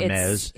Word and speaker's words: Hermes: You don't want to hear about the woman who buys a counterfeit Hermes bag? – Not really, because I think if you're buying an Hermes bag, you're... – Hermes. Is that how you say it? – Yeah, and they're ridiculously...